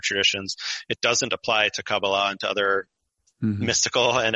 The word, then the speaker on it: Kabbalah: So Kabbalah is the way you'd say it in Hebrew, okay. – but Kabbalah is how you would say it in Yiddish, and thus in Ashkenazi influenced English.